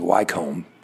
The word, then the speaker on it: Wycombe: So even though it's spelled W-Y-C-O-M-B-E, which looks like Wycombe, it's Wycombe. Wycombe Wanderers. Goal by number by Horgan in the 76th minute. I don't know what number he is. Nicknames for the Wycombe Wanderers include the Chair Boys and the Blues.